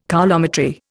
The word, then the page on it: coulometry: Indian